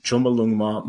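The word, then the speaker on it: Chomolungma: And then 2021 or 2022, the biggie Chumbalungma Mount Everest. – Exactly. You said it right.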